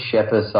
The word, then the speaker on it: Schepis: Schepis. I've been, used to dealing with the Schepis for a long time, so close enough, Abel.